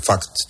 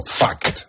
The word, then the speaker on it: fact: Que el fact este hay que tener cuidado con cómo se pronuncia. – Sí, puede parecer no, has dicho otra cosa, has dicho vale. Fact, y eso es otra cosa. – Vale, pues dilo tú bien, ¿cómo es fact? – Fact, no, fact.